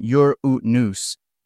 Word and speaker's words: Uranus: The pronunciation Uranus is perfectly valid and widely used in scientific circles.